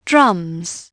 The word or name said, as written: drums